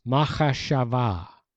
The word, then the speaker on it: machashava: It's machashava. Should I spell it? Yes, Sandra is saying somewhere in cyberspace. Please spell it. M-A-C-H-A-S-H-A-V-A-H.